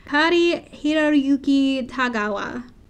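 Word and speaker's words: Cary-Hiroyuki Tagawa: Kari Hiroyuki Tagawa. That is loosely how you pronounce it.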